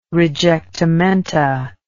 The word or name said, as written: rejectamenta